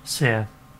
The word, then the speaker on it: Ceres: How do you pronounce her name? – Seer. – Is it? – Yeah.